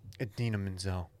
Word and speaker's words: Idina Menzel: I don't know if you guys saw this, but the wickedly talented Idina Menzel.